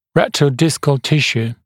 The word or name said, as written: retrodiscal tissue